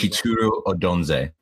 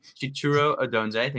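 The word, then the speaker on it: Chituru Odunze: Ethan Horvath, Chicho Oduzie. Is that pronounced correctly? – That was not close. – Go ahead for me. – Chituru Odonze. – Okay. Chituru Odonze. Thank you very much.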